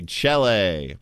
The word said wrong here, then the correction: chelae: C-H-E-L-A-E. – Apparently the singular is pronounced kela.